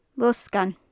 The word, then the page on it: losgann: gaelic